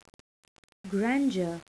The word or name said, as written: grandeur